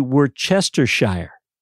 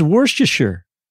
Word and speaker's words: Worcestershire: If you were just to phonetically pronunciate this word right here, this label, it would be Worcestershire. Worcestershire. But that isn't how you pronounce it, is it? – No, it's not. – It's Worcestershire. Worcestershire.